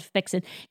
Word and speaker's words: Fixin: It's spelled Fixin. I should have said that. Yes. Thank you for bringing that up. It's called Fixin.